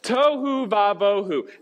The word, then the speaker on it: tohu vavohu: Tohu vavohu. It's a Hebrew phrase. It rhymes. It's cute.